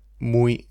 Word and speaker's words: muy: Es una ciudad muy grande.